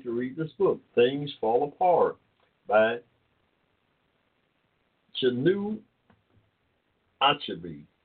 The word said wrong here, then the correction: Chinua Achebe: Okay, Chinu, C-H-I-N-U-A, Chinua Achebe. I'm not that good at pronouncing some of the African names. A-C-H-E-B-E, Achebe. Chinua Achebe, Things Fall Apart.